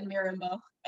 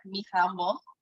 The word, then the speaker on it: Mirembo: So I always said Mirembo. In French, it's like Mirembo.